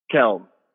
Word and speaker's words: Kelm: Kyle, and Kyle, I really should have asked you before I say Clem. What's the pronunciation of your last name? – Kelm. – Kelm. – K-E-L-M. – Yeah, K-E-L-M.